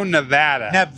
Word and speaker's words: Nevada: So how do you pronounce Reno, Nevada? – Nevada.